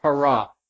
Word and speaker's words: para: And then there's a Greek preposition that means that is would be pronounced para.